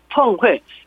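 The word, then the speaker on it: 펑회 (峰会): 발음은 둘 다 사성을 써갖고 펑회.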